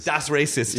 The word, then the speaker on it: Das Racist: Das Racist. – Das Racist.